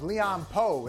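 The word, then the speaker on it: Leon Powe: Leon Poe, that's how you pronounce his name, had like 20-something points after the game.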